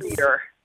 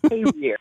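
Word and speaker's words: Carriere: He lives in Career, Mississippi, out in the woods. – Career. – Yep. – Career. That's how they pronounce it.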